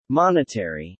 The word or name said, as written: Monetary